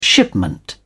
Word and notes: shipment: British English

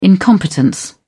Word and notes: incompetence: British English

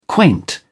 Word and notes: quaint: British English

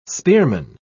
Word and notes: spearman: British English

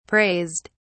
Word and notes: praised: British English

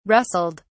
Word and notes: rustled: British English

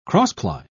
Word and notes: cross-ply: British English

